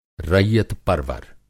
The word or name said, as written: रैयत-परवर